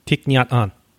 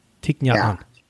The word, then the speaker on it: Thich Nhat Hanh: How do you pronounce that? Thich Nhat Hanh. Thich Nhat Hanh.